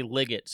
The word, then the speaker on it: Liggett: I usually just say Liggett, so almost like I pronounce that E as just another I, but probably pronounced correctly is exactly how you say it.